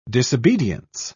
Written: disobedience

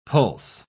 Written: pulse

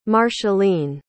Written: marcheline

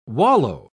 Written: wallow

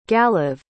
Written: galav

